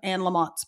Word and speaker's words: Anne Lamott: In fact, I just bought it and it was like the 25th anniversary or whatever of Anne Lamott's book.